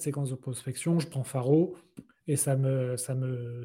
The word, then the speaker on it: Pharow: Et là, tu nous dis qu'en gros, Faro, d'ailleurs, comment ça s'écrit, Faro P-H-A-R-O-W.